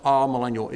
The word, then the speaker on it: amillennial: This means, it's pronounced amillennial. It means non-millennial. This